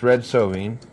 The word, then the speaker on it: Red Sovine: It's called Teddy Bear, and I believe it's his name is pronounced Red Sovine.